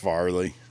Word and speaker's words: Fairleigh: Is it Farley or Fairleigh? I feel like you're pronouncing that wrong.